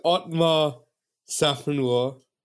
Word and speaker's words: Otmar Szafnauer: This is Otmar Szafnauer, who developed. Not bad. – Not bad. Go on. I pride myself on my pronunciation. Very good.